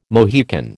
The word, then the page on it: Mohican: American English